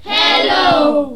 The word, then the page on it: hello: english